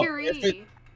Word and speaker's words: Curie: It's no, Marie no. Curie. – Yes!